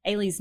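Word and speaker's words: Eilidh: I'm going to spell it for you because Ailey's not pronounced the way it's spelled, as you'll soon find out. It's E-I-L-I-D-H. Double underscore Cameron, C-A-M-E-R-O-N.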